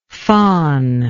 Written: fon

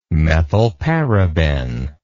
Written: methylparaben

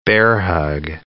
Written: bear-hug

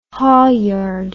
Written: haulyard

